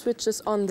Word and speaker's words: switches: The pilot always switches on the lights. – Ja, du hast gerade schon richtig ausgesprochen. Switches on. Denn das Wörtchen switch endet ja schon auf einen Zischlaut. Da kann ich kein S anhängen.